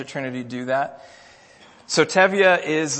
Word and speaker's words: Tevye: So you have, that's pronounced Tevye, if you've never seen Fiddler on the Roof.